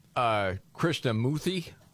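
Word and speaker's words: Krishnamoorthi: The Democrat, Krishnamoorthy.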